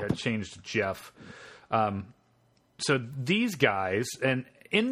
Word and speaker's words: Gef: But according to them, at least, it wasn't always named Jeff, which is spelled G-E-F, by the way.